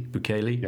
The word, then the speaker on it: Bukele: And, and listening to Peter's uh, interview with um, President uh, Nayib uh, can't pronounce his surname, Bukele. Bukele.